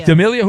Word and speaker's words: D'Amelio: Dixie D'Amelio. Is that, am I saying that right? Yeah, but 25. Is that an actress? – D'Amelio.